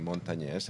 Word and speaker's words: Montañez: Really spectacular pedigree and curriculum in Tia Montañez, and I want to emphasize the ñ in Montañez.